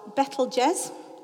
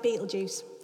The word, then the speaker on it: Betelgeuse: And then the red one up there, that's called Betelgeuse. Said it right. Looks like Betelgeuse.